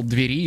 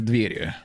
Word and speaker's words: двери: Я вначале записал двери и потом такой, что-то здесь не так. Пошел загуглил, оказывается, надо говорить правильно двери, вот.